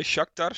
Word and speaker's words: Shakhtar: Ja, dat is, dan moet dus op de laatste dag moet Atalanta winnen bij Shakhtar.